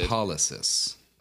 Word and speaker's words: lipolysis: Lipolysis.